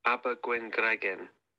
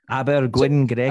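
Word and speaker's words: Abergwyngregyn: I believe you pronounce it Abergwengregen, but don't quote me on that because I'm not from North Wales. But shame on you for not being able to say it anyway. – Oh, Abergwengregen. – So, I mean, to be fair, like the name literally has like a G-W-Y-N G, you know, all the letters that they shouldn't be together are together, which is pretty much most of the Welsh language.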